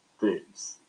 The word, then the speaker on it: Toews: Toews.